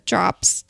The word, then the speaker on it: Dropps: And drops is spelled DROPPS.